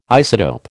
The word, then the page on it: isotope: American English